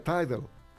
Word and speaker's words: Tidal: MQA lo pone en este momento en la cima una empresa que se sirve Tidal y se pronuncia Tidal.